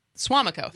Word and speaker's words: Suamico: Tell me if, Brian, let me know if I'm saying that right or if we're saying that right. And I do actually, besides the volcanoes every once in a while, Hawaii is very different than it feels like the Suamico. – Yeah.